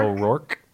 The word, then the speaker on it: O'Rourke: O'Rourke. – O'Rourke.